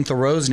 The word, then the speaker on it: Theroux: We've been saying Justin Theroux's name incorrectly this whole time. His uncle is an author, and he says they've always pronounced it Theroux because it's French.